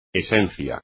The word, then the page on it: esencia: espagnol